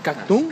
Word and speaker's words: Katun: Ok, bueno, para, antes de seguir con la entrevista, eh, del disco Catun, así se pronuncia ah, Katun.